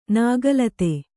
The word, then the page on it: ನಾಗಲತೆ: nāga late